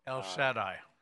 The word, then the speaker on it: El Shaddai: En hebreo, El Shaddai.